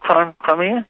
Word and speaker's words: Crimea: Is that how you pronounce it? Crimea? – Exactly, exactly.